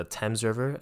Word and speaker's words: Thames: And again, I haven't been this far out, but it is next to the Thames River. That's how they say it, the Thames River.